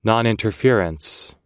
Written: noninterference

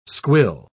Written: squill

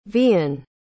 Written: vian

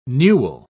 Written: newel

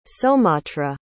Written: somatra

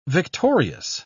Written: victorious

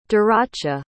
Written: daracha

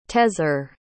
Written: tezer

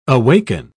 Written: awaken

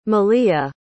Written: melea

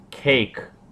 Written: cake

